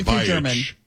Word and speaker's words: Beich: Because we were trying to pronounce, I was trying to pronounce a reporter's name, and it's spelled like, itch. Something like, it's like that. – But it's pronounced exactly that. – Beich. – From what we understand, right. It's yes. pronounced Beich.